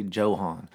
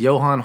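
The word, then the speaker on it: Johann: I read his book, and it's spelled with a J. I say Johan. No, it's Johan Hari.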